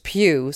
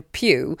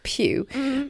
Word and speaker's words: Pugh: Men det är ju tack vare egentligen Florence Pugh, som jag hörde att man uttalar hennes namn nu. Jag trodde det var Pug, men hon heter tydligen Pugh. – Pugh.